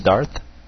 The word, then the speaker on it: Darth: Qui dit Dark? Je dis pas Dark. Moi, je dis Darth.